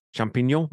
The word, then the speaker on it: champignon: Is that how you pronounce it? Champignon? Champignon? That's French for mushroom, right? So well, yes, it is, and you're really doing a nice job of the pronunciation.